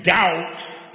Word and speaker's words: doubt: It comes actually from the word double where we do pronounce the B. – Double. – D-O-U-B-L-E. Double. And the real meaning of to doubt is to double.